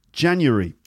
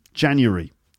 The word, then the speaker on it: January: How many syllables does it have? Well, it's January. January. That's right. Now, you might be thinking, wait a minute. Isn't it January? Well, yes. Some people might say January. That's four syllables.